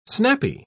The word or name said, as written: snappy